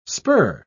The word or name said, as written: spur